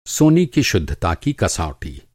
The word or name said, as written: शुद्धता